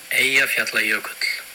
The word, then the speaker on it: Eyjafjallajökull: So if you press the play button there and hear the proper Icelandic pronunciation. – Eyjafjallajökull.